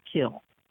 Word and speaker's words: kiln: Kiln is spelled K-I-L-N. The British do not pronounce the N. They call it kiln. I studied under an Anglophile. In my classes, I was not allowed to pronounce the N. You can say kill or kiln.